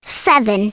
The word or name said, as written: seven